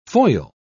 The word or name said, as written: foil